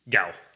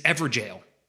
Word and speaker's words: gaol: The way to pronounce it is Everjail. – Oh. – G-A-O-L. – Okay. – That's the fucking etymology of the word jail.